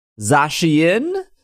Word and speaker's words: Zacian: We have actual pronunciations on this. Zacian